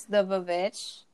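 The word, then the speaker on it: The VVitch: So you've heard us previously talk about this film and our constant argument about how it's pronounced, and it's the Vavitch.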